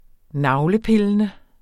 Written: navlepillende

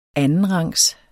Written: andenrangs

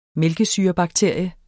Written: mælkesyrebakterie

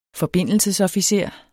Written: forbindelsesofficer

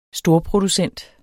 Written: storproducent

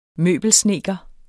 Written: møbelsnedker

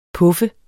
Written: puffe

